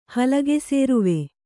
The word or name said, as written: ಹಲಗೆಸೇರುವೆ